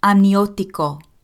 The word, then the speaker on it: amniótico: And you can hardly hear the last syllable there. It's líquido amniótico.